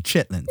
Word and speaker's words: chitterlings: Because there is an R in there. – I'm sorry. I know there's an extra R. I know, but chitlins. – I just got to say it like that.